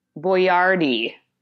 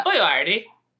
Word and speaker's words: Boyardee: She wanted to do pasta, and I decided to do America's favorite chef, Chef Boyardee, because that's actually how you pronounce his name. – Boyardee. – Yes. So, Chef, but it's crazy because, you know, my whole life I've been calling him Chef Boyardee, and that would be French. – That is not how you say his name. His name is pronounced Boyardee.